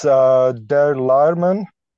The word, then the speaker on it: Der Leiermann: It's Der Lehrmann.